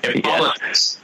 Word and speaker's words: Hippolytus: Hippolytus. Yeah. And that, by the way, Bob checks, that's the right pronunciation.